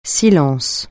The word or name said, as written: silence